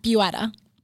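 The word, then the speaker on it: Buitta: It's Dana Buetta. – Buetta. – Yes. – Okay. So it's B-U-I-T-T-A.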